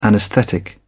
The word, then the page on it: anaesthetic: British English